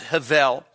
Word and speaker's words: Hevel: And so we're going to begin with Hevel, which you see in your notes, H-E-B. The B is pronounced as a V, so it's Hevel.